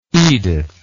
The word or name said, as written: edith